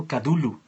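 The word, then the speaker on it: Cthulhu: los españoles lo pronuncian Cadulu.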